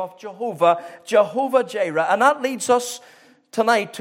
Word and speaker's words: Jehovah Jireh: And I actually watched some Hebrew videos on pronunciation of that word. So I've got it as well as it is on the internet. Jehovah Jireh.